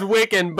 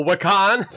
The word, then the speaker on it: Wiccan: Yeah, the character's name is Wiccan, but my dumb ass was like, oh, Wakan?